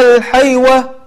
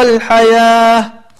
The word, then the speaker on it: الحياة: وكلمة الحياة لو نلاحظها أنها مكتوبة بالواو، ولكن هذا هو الرسم العثماني، بعض الكلمات تكتب بالواو ولكنها تنطق بالألف، مثل الزكاة أو الصلاة أو الحياة. طيب فتلاحظ أنك تقول وآثر الحيوة مثلا، ولكن تقول وآثر الحياة.